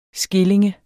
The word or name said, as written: skilling